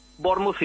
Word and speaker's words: Bournemouth: Escucha, ¿cómo se pronuncia? Porque yo he oído que se dice Plymouth y Bournemouth. – Es Plymouth, Bournemouth y Portsmouth.